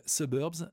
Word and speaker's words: SBRBS: Le groupe s'appelle Suburbs, ça s'écrit uniquement avec les voyelles, S-B-R-B-S, un duo qui a vraiment un son énorme et dont j'aime beaucoup le tout nouvel album The Devil You Know.